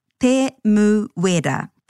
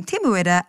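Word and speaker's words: Temuera: He may actually be often known as Tim, but to pronounce his full name, Te Muera, again, stay true to the vowel sounds, a, e, I, or u, and break the name into syllables, which will always end with a vowel.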